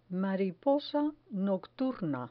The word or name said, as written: mariposa nocturna